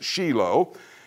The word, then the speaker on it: Shiloh: It's not pronounced Shiloh in Hebrew, it's Shiloh.